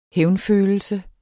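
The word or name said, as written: hævnfølelse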